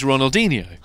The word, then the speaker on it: Ronaldinho: It's Ronaldinho.